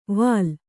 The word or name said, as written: ವಾಲ್